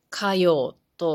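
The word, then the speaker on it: 花葉: 花の葉っぱと書いて、かようと読みますが、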